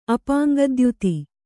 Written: ಅಪಾಂಗದ್ಯೋತಿ

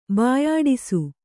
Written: ಬಾಯಾಡಿಸು

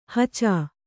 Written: ಹಚ

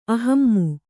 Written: ಅಹಮ್ಮು

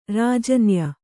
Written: ರಾಜನ್ಯ

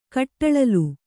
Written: ಕಟ್ಟಳಲು

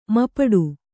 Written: ಮುಪ್ಪಟ್ಟೆ

